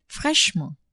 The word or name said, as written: fraichement